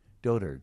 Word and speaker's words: dotard: Dotard. – Dotard. – I thought it was dotard. – I did too. That's the way I would have pronounced it. But now I've learned it's dotard.